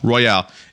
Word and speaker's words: royale: a battle royal type game. So this is a computer game. – Is it royal or royale? – Royale. It's I say battle royal, but it's spelled battle royale.